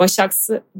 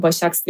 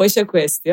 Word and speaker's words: Başaks: Yani Başaksı benim arkamda da Başaks diye okunuyor buradan herkese söylemek isterim. Babam da bana Başak West diyor ama Başaks aslında.